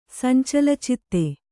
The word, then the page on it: ಸಂಚಲಚಿತ್ತ: sancala citta